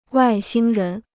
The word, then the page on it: 外星人: wài xīng rén